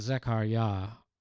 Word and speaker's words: Zechariah: I love the fact that his name, Zechariah, emphasis on the Yah, which is contraction for Yahweh.